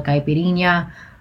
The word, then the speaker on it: caipirinha: And it was silver cachaça, which is what's used in a Brazilian drink called a caipirinha, with Earl Grey tea, lemon juice, simple syrup, and rose water.